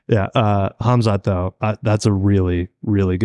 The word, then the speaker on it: Khamzat: And do we know how to actually pronounce it? Is it Khamzat? – Khamzat. – Khamzat? – Hum. – Ivan sounded pretty confident. – Like, yeah, I'm going to believe Ivan in this one.